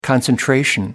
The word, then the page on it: concentration: American English